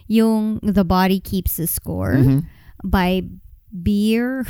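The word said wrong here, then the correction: Bessel van der Kolk: I don't know how you say his name. Or no, not Beer. Bezel van der Kolk.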